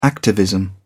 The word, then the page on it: activism: American English